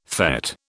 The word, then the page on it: fête: British English